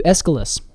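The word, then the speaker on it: Aesculus: I would just do Aeschylus. – Aeschylus. – Yes, I wouldn't put so much emphasis on the U and the U.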